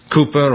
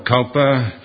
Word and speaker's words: Cowper: We've actually sung three hymns tonight that were written by the man, William Cooper or Cowper, depends how you pronounce the name.